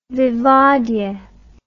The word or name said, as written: vivandier